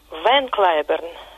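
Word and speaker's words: Van Cliburn: Его звали, ну вот в английском произношении, Харви Лейвен Клайберн. Ван Клайберн – это не искаженное голландское Ван, а уменьшительное от его второго имени Лейвен.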